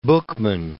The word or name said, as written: Buchman